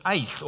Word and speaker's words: eth: But this word time is eth, or E-T-H it's spelled, eth as it's pronounced.